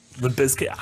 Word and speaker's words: Limp Bizkit: Limp Bizkit. I like that you accurately pronounce the Z.